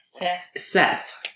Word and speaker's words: Set: She next went on to be a follower of the ancient deity of Seth. – And what is Seth? – It's spelled S-E-T. – Set. – But it's pronounced Seth. – With a T-H.